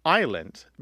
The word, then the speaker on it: island: So we don't pronounce the S in island because the French don't pronounce the S in aisle.